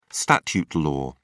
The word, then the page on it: statute law: British English